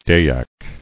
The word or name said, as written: Dyaks